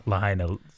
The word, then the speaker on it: Lahaina: The Lahaina Civic Center. – Well done. Is that how you say it? – Lahaina Civic Center.